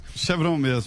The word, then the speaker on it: Chevron: A Petrobras diz que assinou a venda da refinaria de Pasadena por $562 milhões para a empresa norte-americana Chevron. Como é que se fala isso aí? Como é que se pronuncia? Você deve saber. É isso, é assim mesmo. Para o negócio... – Chevron mesmo.